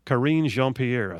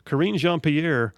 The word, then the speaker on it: Karine Jean-Pierre: So Fox News' Peter Ducey, who just asked the best questions, he literally asked White House Deputy Press Secretary, and I hope I say her name right, Karine Jean-Pierre, I think is the way she pronounces her name. Karine Jean-Pierre, who is as much of a hack as Jen Psaki, backtracked on Monday.